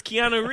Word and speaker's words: Keanu: it's Keanu Reeves.